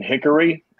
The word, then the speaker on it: Hickory: For whatever reason, we don't pronounce the O in Hickory. It's just Hickory.